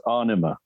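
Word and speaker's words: Ænima: The album will be pronounced Enema. – Enema. – And okay. – it's the female form of the Enema. So it's the AE is the Enema.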